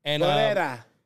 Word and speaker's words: Pereira: Peoria. – Alex Pereira. – Pereira.